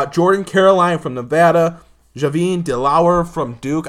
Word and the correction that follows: Delaurier: I don't know if I said that right. – Javin Delaurier.